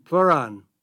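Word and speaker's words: pleuran: It's spelled P like in Peter, L-E-U-R-A-N, Pleuron.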